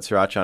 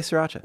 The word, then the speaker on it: sriracha: Our friend Anthony Lopez calls it sriracha. And I want to rip his fucking throat out every time he does it. That's probably how you say it, but it's one of those things where I'm like, just say sriracha.